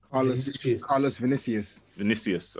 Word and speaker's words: Carlos Vinicius: I don't know if how do you pronounce this? – Carlos Vinicius. – Okay, yeah. So Carlos Vinicius.